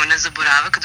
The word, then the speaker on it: nezaboravak: Dakle, ako sam dobro akcentovao, nezaboravak, potočnica, zmijske oči. Zaista jedan onako zanimljiv i upečatljiv naslov, pa kako je sve to do toga došlo? – Mi ga akcentujemo nezaboravak, doduše imali smo dileme.